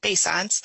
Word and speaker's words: Beauchamps: I'm Sarah Beauchamps. If you see my name spelled, it's just impossible to pronounce it correctly. So Beauchamps.